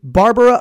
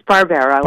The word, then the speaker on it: Barbera: Uh, we're talking to, and by the way, is is the last name Barbara or is it Barbera? Am I pronouncing it correctly? – It is Barbera, Barbera. – like Hanna Barbera.